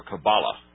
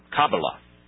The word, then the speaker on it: Kabbalah: I was never given any information about this whole business of the, do you pronounce it the Jewish Kabbalah or Kabbalah? – The correct pronunciation is the Kabbalah. – Kabbalah. – But there's a kind of an anglicized version, which is the Kabbalah.